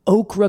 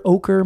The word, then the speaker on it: ochre: The trees, the grasses that are growing there, this kind of like okra color over here, ochre maybe, I guess is how you pronounce it.